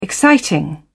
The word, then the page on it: exciting: British English